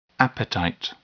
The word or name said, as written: appetite